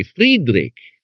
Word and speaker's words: Friedrich: In German, even to transliterate it, you would not pronounce it Frederick. You would say Friedrich, because that's the way it's pronounced in German.